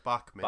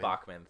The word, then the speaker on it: Bachman: That's pronounced Bachman. – Bachman, thank you.